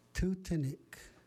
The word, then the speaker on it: Tutanik: The word for hair, Ichishkin, Tutanik, Tutanik.